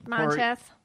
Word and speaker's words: Monteith: Monteith.